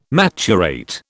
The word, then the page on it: maturate: British English